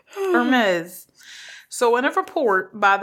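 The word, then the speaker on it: Hermès: Hermes. – Okay, is you pronounce the H? – Yes. It's like you pronounce it, but you don't.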